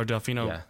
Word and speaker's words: Delfino: That was waiting for, I think I pronounced it, Delfino Square? – Yes. Or Delfino, yeah, Delfino Square, yeah.